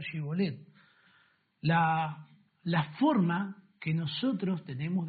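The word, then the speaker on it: Shibolet (שיבולת): Si él respondía no, entonces le decían, ahora pues, di Shibolet. Y él decía Sibolet, porque no podían pronunciarlo correctamente.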